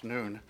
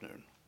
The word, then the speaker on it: Nun: Now, one of the things that we're going to, probably pronounced Noon,